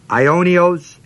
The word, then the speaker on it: Aionios: This word everlasting is the Greek word Aionios, A-I-O-N-I-O-S, A-I-O-N-I-O-S. First O is a long O.